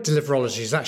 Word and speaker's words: deliverology: And so the concept you kind of made famous is this concept of deliverology.